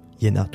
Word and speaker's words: Hyena: À présent de découvrir Yena, qui s'écrit H-Y-E-N-A, originaire de Bolivie, de La Paz notamment, et d'une famille quechua, mais qui vit maintenant plutôt à Bruxelles.